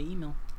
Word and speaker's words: Imil: So, Emil, I don't know if I'm pronouncing it right. I-M-I-L, Golden Sun. – I would say Emil.